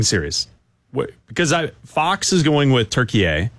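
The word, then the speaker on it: Türkiye: They pronounce it turkey A. – Turkey A. – It's pronounced turkey A.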